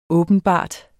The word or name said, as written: åbenbart